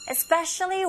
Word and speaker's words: especially: Now try the second listening point, that is, especially when the female player gets attacked. 이스페셜리가 아니라 에스페, 에 이 부분에 강세 주시고 앞부분에 이스페는 아주 빠르게 especially 거의 안 들리죠. especially 근데 발음하셔야 돼요.